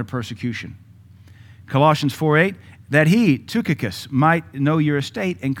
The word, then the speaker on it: Tychicus: Ephesians 6:22, Paul said, and that he, Tychicus, or Tychicus, it's Greek pronounced Tychicus, Tychicus might comfort your hearts.